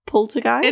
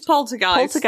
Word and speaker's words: poltergeist: Poltergeist? – It is poltergeist and not poltergeist.